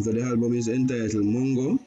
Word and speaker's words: Moengo: M O E N G O Moengo, and that's Samara Samora um, new album, and that's S A M O R A, right?